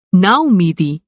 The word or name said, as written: नाउम्मीदी